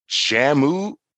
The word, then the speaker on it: Jamu: This one's called Jamu Juice. And that's J-A-M-U. If I say that wrong, Jamu, I'm not too sure.